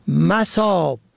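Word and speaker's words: مَصاب: مساب به فتح اول جمع مسب به معنای محل مورد موضع، مساب به کسر اول به مفهوم سیراب پر و فرد سیراب و پر شده از آب.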